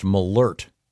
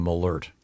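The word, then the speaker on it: Malört: And this says it's pronounced Malort. – Malort. – There's a, was it an umlaut, the two dots over the O? This has a pronouncer saying Malort.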